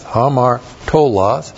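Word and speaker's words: hamartolos (ἁμαρτωλός): Looks like that in the Greek, H-A-M-A-R-T, long O-L-O-S, hamartolos.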